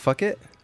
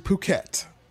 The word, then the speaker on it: Phuket: Oh, it's not fuck it? – It's actually Phuket. That's how they pronounce it.